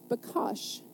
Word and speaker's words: bakash: In the Hebrew, it's bakash, is how it's pronounced.